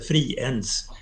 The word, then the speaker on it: Friends: Språk, alltså uttalsreglerna i svenska gör att den heter Friens och inte någonting annat när den nu är nationalarena.